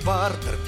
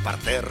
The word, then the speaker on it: партер: А если вы в театр придете, например, вас не пропустят в партер, пожалуйте в партер. – Вас не пропустят в партер, пожалуйте в партер. Товарищ с ударением, внимательнее будь, и наши наставления мы просим не забудь.